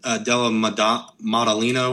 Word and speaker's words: Della Maddalena: But Jack Della Donna. Della Maddalena.